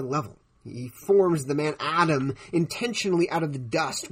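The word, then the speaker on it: Adam (אָדָם): You see, because the man, which by the way in Hebrew is pronounced Adam,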